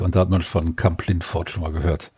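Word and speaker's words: Kamp-Lintfort: Hallo Dennis aus Kamp-Lindfort.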